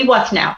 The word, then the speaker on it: Withnall: Debbie, are we saying your last name right? Because I keep feeling like I'm saying it wrong. Are we okay? – I say Debbie Withnall.